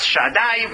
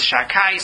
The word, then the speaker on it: Shaddai: Then, the flip side is the name of Din, of justice, and for that he applies the name Shin Dalet Yud, pronounced in its correct form as Shaddai. We usually render it as Shakai, so as not to say this name of God.